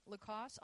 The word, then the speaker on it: Lacoste: The E is silent. Lacoste, undrafted rookie out of Illinois, pulled in some receptions as well and has looked good.